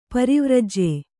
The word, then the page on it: ಪ್ರವ್ರಾಜ್ಯ: pravrājya